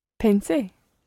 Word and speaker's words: pensé: Pensé. – Pensé. And where, tell me, is the stress on that word? – On the last syllable? – Exactly, it's on the last syllable.